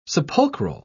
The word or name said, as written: sepulchral